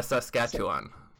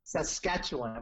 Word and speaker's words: Saskatchewan: In the how do you say that? The Sasquatch province? – Saskatchewan. – Saskatchewan.